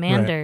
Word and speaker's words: MNDR: and it was under Amanda's stage name Mander, right, which is spelled MNDR. You and I were calling her MNDR for a while. I didn't realize it actually had a spelling. It actually had a sound to it.